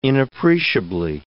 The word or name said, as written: inappreciably